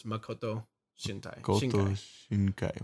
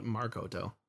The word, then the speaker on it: Makoto Shinkai: Well, you threw an R in there, isn't it? It's Makoto Shinkai. – Where did I throw that? – You put Markoto.